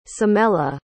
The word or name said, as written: samella